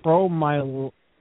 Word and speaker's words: promyelocytic: But acute promyelocytic, can you just pronounce that for me because I feel stupid? – Promyelocytic.